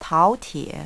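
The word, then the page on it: taotie: language Chinese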